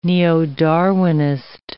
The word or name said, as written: neo-Darwinist